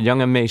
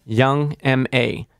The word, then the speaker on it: Young M.A.: Say her name again. – Young M.A. – Young M.A. – Don't call her Young Ma, like a bunch of the guys did at Webster Hall house party when she came out with Flex.